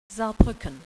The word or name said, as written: Saar